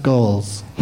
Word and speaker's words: Goelz: I think it's pronounced Goals. Goals. – Is it pronounced Goals? – Yes.